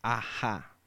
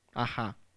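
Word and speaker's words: a-ha: Y es de esto los famosos Ajá. Sí, ¿no? Así se pronuncia. Ajá.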